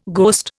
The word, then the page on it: ghost: Indian